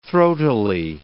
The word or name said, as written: throatily